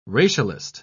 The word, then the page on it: racialist: British English